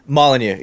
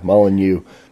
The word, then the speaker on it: Molyneux: It's called "Pretty Kitties Lick While Listening to Stefan Molyneux." Is that how you say his name? – Molyneux. Yeah, yeah. – Yeah, Molyneux.